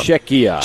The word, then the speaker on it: Czechia: Czechia.